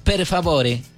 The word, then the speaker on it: per favore: Per favore. – Per favore. – Per favore. Y hay que ponerle el acento porque uno se no dice per favore, no dice per favore.